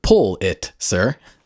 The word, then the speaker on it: Pulitzer: They say, how is Pulitzer pronounced? The correct pronunciation is pull it, sir.